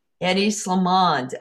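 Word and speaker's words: Hedi Slimane: Heidi Silliman, is like one of the most, the coolest people in the fashion that people look up. People like him. – Eddie Slimane.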